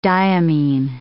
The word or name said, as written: diamine